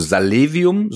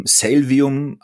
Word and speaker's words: Salevium: Also Salevium, so sprechen wir es aus und so wollen wir es auch, dass es gerne in Deutschland ausgesprochen wird. Salvium haben wir auch schon gehört. So könnte es vielleicht irgendwann mal international heißen.